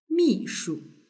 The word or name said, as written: mishu